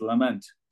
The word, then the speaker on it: Lamont: Well, that's a good one, because Andrew Neil, who was editor of the Sunday Times when Norman Lamont was Chancellor, always insisted that the Scottish or the Ireland's pronunciation of Lamont was Lament.